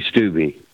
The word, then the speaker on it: Stube: By the way, am I pronouncing your last name correctly? – It's actually Stube.